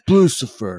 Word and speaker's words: Blucifer: If you look up Lucifer, quick side note: when we did our podcast on this, I mispronounced it and I called it Bluticris, which is also very So we called it Bluticris. Everyone's like, it's Blucifer.